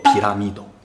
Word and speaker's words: 日来玉堂: 我们现在叫出云大社，它出云嘛，原先啊叫日来玉堂啊，哎，就太阳升起来的地方，日来玉堂，读作皮拉密斗。